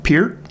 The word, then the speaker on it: Peart: Everybody else, Americans pronounce it Peart. – Yeah, it's Peart. Yeah, it's like Peart. Ear.